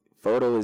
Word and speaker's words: Foto: Why F-O-T-O? – F-O-T-O because it's simplified. I like to simplify everything, you know? And so Photo, that's how you say it, you know, without the P-H, you say it with a F, you know?